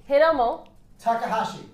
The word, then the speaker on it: Hiromu Takahashi: Hiromu Takahashi.